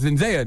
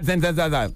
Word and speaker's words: Zendaya: She was like, it's your job to tell them that it's Zendaya, not Zendaya.